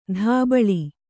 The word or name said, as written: ದಬ್ಬಳ